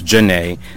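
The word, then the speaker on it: Zhané: Pronounced Janae, that's Z-H-A-N-E, with Hey Mr. DJ.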